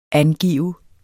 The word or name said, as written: angive